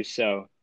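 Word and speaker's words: Rousseau: Oh, it's Rosu. – Oh, maybe we're all stupid. – Yeah, all right, well, he's super dominant. – Rosseau. That's how you say it. Rosseau. Gregory Rosseau.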